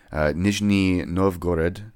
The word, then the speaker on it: Nizhny Novgorod: So now that I've beat the fucking dead horse, they are a three-piece mathcore band from Nizhny Novgorod, Russia.